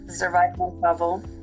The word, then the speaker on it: Zoraida Montalvo: I was close. I was trying to spell it how I see it, how it's, I was going to say more Zoraida, but thank God you corrected that.